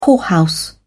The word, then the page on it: whorehouse: British English